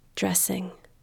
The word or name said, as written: dressing